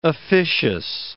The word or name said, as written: officious